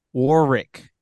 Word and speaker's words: Warwick: What is the big deal about how do you pronounce this raggedy ass man's name? – Replace the second W with an R and you got it. – Warwick.